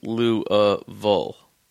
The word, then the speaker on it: Louisville: If you're from there, it's pronounced Louisville.